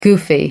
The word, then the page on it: goofy: American English